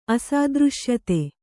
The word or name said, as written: ಅಸಾದೃಶ್ಯತೆ